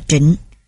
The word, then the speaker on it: Trịnh: Trong âm tiếng Hán, chữ chính có thể đọc là Trịnh, nên là chính Nam Phong hay Trịnh Nam Phong cũng không sai.